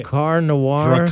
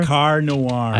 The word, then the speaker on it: Drakkar Noir: Drakkar Noir. – Drakkar Noir.